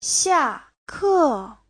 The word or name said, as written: xia4ke4